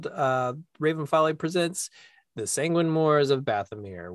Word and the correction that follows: Bathomir: You said it wrong. – Did it's Sanguine it? – Moors of Bathomir.